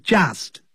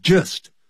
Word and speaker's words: just: When I was just 1 year old，当我只是，这个 just 就等于 only，不要念成 just，要念成 just，肚子饿的饿饿 just。